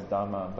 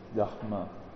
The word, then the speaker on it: Dharma: So for example, Dharma, we usually pronounce as Dharma, but the more correct pronunciation is Dharma.